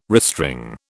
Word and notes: restring: British English

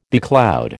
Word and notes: becloud: American English

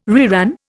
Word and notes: reran: Indian

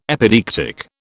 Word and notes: epideictic: British English

